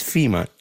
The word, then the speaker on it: Fima: My name is Fima, just like the organization, but spelled with an I.